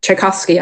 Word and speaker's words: Tchaikovsky: He loves classical music, and there's this one um, Tchaikovsky, I can, Tchaikovsky actually pronounced it right, tune that he loves, and he hums it in the shower.